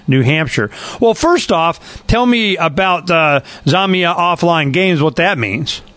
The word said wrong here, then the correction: Zomia: Yeah, it's pronounced Zomia. And Zomia.